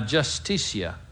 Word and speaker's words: justicia: And I think in the Spanish it says la justicia. Is that correct? La justicia? Huh? – La justicia. – La, oh, you pronounce it. Justicia. Justicia. You know, the J is a H, right.